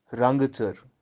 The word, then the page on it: rang-tser: tsar